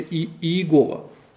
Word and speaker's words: Иегова: Оно произносится как Яхве, а в научной литературе, в русской литературе с 19 века начиная, закрепилось произношение Иегова.